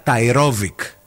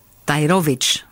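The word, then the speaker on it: Tairović: Η, η, η Τέα Ταϊρόβικ. – Ταϊρόβιτ. Ταϊρόβιτ. – Ταϊρόβιτ. Το σύ στα κυριλικά διαβάζεται τσ.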